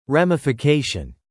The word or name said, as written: ramification